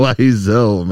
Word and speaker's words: Wiseau: Wiseau, man.